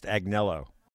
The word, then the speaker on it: Agnello: My new joke is, I'm sorry, it's pronounced Agnello.